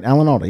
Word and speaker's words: Alan Alda: Alan Otta was born. Alan Otta was born. Did I say Alan Alda? Did I say that right? Alan Alda, yeah.